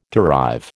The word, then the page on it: derive: American English